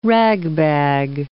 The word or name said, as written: ragbag